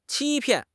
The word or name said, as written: 欺骗